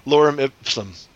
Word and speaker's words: Lorem ipsum: Did I say that right? – Lorem ipsum.